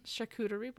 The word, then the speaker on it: charcuterie: When Magnus wakes up, Alec is on the balcony with Prosecco and a shark coochie board. – Mm-hmm. – Charcuterie board? – Yeah. – I can never say that correctly.